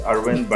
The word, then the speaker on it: Arvind: Yes, you called it called my name correct. It's Arvind, but in a boundaryless world, I think every pronunciation is acceptable as long as it meets the certain syllables of the name.